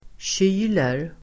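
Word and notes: kyler: [tj'y:ler]

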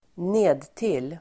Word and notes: nedtill: ²n'e:dtil:

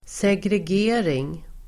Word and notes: segregering: [segreg'e:ring]

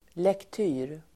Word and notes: lektyr: [lekt'y:r]

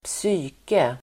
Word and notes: psyke: [²ps'y:ke]

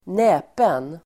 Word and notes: näpen: ²n'ä:pen

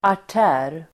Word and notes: artär: [ar_t'ä:r]

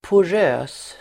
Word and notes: porös: [por'ö:s]

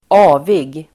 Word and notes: avig: [²'a:vig]

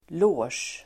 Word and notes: loge: [lå:sj]

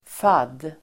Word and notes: fadd: fad:

